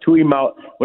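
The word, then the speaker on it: Tuimoloau: JT Tuimolo. I can't pronounce it. Tuimaloau? Something like that. – That sounds right.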